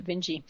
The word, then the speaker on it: Vinge: I think she pronounces it Vinge, Joan Vinge, V-I-N-G-E, Snow Queen and Summer Queen duology.